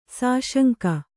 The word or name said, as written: ಸುಸಂಗ